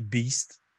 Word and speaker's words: Baest: Beast, ouais, ben ça s'écrit Baest, mais c'est Beast.